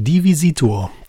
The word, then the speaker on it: Die Visitor: Jetzt weiß ich ja endlich, wie die Band ausgesprochen wird, nämlich Die Visitor.